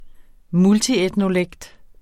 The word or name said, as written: multietnolekt